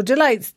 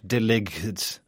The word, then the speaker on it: delights: Yes, so delights, D-E-L-I-G-H-T-S. Here it's a verb. – Yes, not deligs. – No, there's a G in there that you don't pronounce. It's delights.